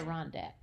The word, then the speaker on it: Adirondacks: Adirondacks.